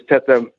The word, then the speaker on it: Teda: But it's pronounced Teda, right? And it comes from the Cantonese spelling of pronunciation, which is Teda, which the Kung Fu people call it. Tet meaning iron, Da mean hit.